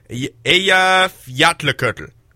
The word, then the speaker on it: Eyjafjallajökull: Eyjafjallajökull.